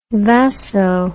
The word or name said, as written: vaso